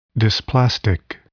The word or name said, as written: dysplastic